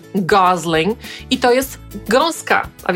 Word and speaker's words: Gosling: Jak pewnie usłyszeliście, on sam wymawia swoje nazwisko jako Gosling przez S.